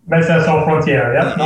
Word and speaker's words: Médecins Sans Frontières: How does Doctors Without Borders, how do you say, Médecins Sans Frontières? Is that, a lot of people would know it as that internationally, is that right? – Médecins Sans Frontières, yep, with apologies for my Francais to everybody.